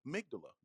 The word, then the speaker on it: amygdala: It's the amygdala. You're like, what is that? It's A-M-Y. It's a real word. G-D-A-L-A. Amygdala. That's how it's pronounced.